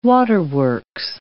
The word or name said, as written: waterworks